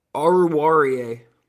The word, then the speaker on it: Oruwariye: Can you say it? Oruwariye. Thank you.